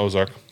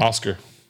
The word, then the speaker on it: Oskar: So nope, no, not Ozark. – Not Ozark? – Oscar. Like O-S-K-A-R.